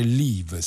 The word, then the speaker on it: Liv: si fa chiamare Live, si scrive scrive liv, L-I-V, tra parentesi, punto E, tanto per rendere la vita più complicata a chi deve poi annunciarla.